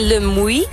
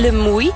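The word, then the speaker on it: Le Muy: En we zijn nu op een fantastische, hele grote camping in Le Mouy. Zo spreek je dat uit, hoor. Ja, ja. – Le Mouy.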